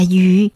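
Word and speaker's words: dụ (鱼): con cá trong tiếng Hoa được phát âm là dụ, từ đồng âm là tu dụ cũng đồng thời có nghĩa là dư giả đó các bạn ạ.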